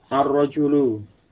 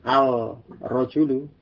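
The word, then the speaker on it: Ar-rajulu (الرجل): Ar-rajulu waqifun. Laki-laki itu berdiri. Ini ar-rajulu. Jadi membacanya demikian ya. Bukan al-rajulu atau al-najmu, tetapi masuk pada huruf sesudahnya.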